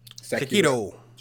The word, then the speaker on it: Sekiro: Sekiro.